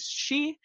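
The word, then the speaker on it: shi (し): And in Japan, the number 4 is considered unlucky because its pronunciation, which is shi, is similar to how you pronounce the word for death.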